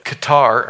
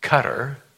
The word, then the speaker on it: Qatar: You know what Qatar, or Terry actually corrected me, it's pronounced Qatar.